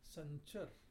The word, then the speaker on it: Sanchara: The cycle of creation is called Sanchar. Sanchar is spelled S-A-N-C-H-A-R-A. Sanchar.